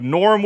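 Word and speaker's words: Gnorm: like Norm with a G, like a silent G, like gnome.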